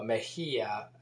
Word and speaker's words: Mejia: Paz, P-A-Z, Mejia is M-E-J-I-A. And I'm pronouncing the J as, it also has an H sound in Spanish. The G has an H sound with the vowels E, I, and, E and I. And has a hard G sound with A, O, and U. But the J in Spanish sounds like an H in English. So Mejia, as if there was an H there, Mejia.